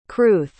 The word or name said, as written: crwth